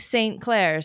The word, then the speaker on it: St. Clair: Or even if you pronounce it, St. John, St. John, St. John, St. Clair. See what I'm saying? St. Clair, St. Clair. Like you kind of drop the T.